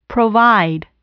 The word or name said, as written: provide